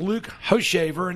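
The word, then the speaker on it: Hochevar: Get a nice cheap ticket and sit right behind the Kansas City dugout and watch a former number one pick, Luke Hoshaver, and that is how you pronounce that name.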